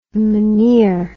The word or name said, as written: meneer